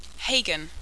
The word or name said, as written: Hagan